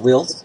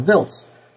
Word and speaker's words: Wiltz: I presume it's Wiltz and not Wiltz. – Wiltz. It's pronounced Wiltz, but you know sometimes the Americans call it Wiltz.